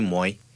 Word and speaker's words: moi: When I got the grasp of how the words were pronounced, like the first time I saw M-O-I, I was pronouncing it moi. – Okay. – But then they corrected me, moi.